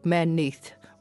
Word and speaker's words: Merneith: And within that cemetery, there's one burial dedicated to a woman called Merneith, or Merneit, depending on how you want to pronounce it.